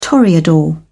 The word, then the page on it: toreador: British English